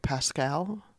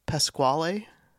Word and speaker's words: Pasquale: Yeah, this is a review of Pasquale's Pizza. Do you know about this? – What? – Pasquale's Pizza. – Not Pascal? – Nope, it's... – Pasquale? – It's Pasquale.